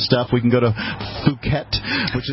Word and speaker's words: Phuket: But in fact, the name of the city in Thailand, and it's spelled with PH, Phuket.